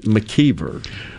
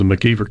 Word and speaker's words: McIver: That was a big debate whether or not we were going to pronounce it McKeever or McIver. You know, it's like, to the uninitiated, when you look at it, it's spelled like McIver, you know, but it is known as the McKeever community.